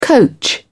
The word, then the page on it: coach: British English